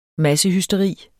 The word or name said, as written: massehysteri